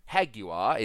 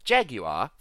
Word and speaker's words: Jaguar: So Haguar is um what Jaguar sounds like if you pronounce it the Spanish way, and Jaguar for those who don't know or Jaguar, pronounced the American way.